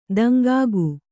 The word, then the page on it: ದಿಂಕಿಕ್ಕು: diŋkikku